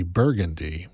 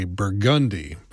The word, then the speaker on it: Burgundy: I'm Josh Jackson, and where I'm from, just outside of New Orleans, we wouldn't say Burgundy. We would say Burgundy, because that's how the street name is pronounced.